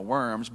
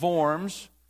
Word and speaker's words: Worms: We spell it Worms. That's how we say it in Alabama, Worms. But the correct pronunciation is Worms.